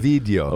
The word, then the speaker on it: Ovidio: Ovidio. – Ovidio, I guess. – O-V-I-D-I-O.